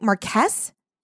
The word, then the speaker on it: Marquess: Marquess? – Yeah.